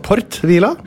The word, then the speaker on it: Port Vila: Det Port Vila. – Ja, det uttales Port Vila.